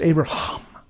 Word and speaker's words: Abraham: See, when they pronounce it in Hebrew, it's the sound of breath. It's Abraham.